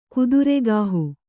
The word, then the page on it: ಕುದುರೆಗಾಹು: kannada